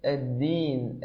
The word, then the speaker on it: الدين: يعني لقد قلت مشكورا الدال في المرة الأولى تقريبا بشكل طيب، ولكن حينما عتاها في المرة الثانية نطقت فيها يعني صوتا شبيها من صوت التاء، فقل الدين الدين الدين الدين. هذا هو إن شاء الله الصوت الصحيح لحرف الدال.